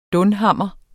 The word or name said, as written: dunhammer